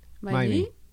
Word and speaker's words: Maimí: Maimí. Maimí. Maimí. Maimí. con acento en la I.